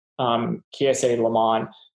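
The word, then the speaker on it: Kiese Laymon: Kiese is K-I-E-S-S-E. And then Layman is L-A-Y-M-O-N, Kiese Layman. is at least how I've been told to pronounce it.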